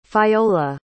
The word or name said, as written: fayola